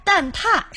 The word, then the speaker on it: 蛋挞: 它的四声是念踏。 – 踏，对，是念蛋挞，是这个读音。因为大家呢就是口语习惯就是蛋挞，但是播音和书面的正式场合是读蛋挞的。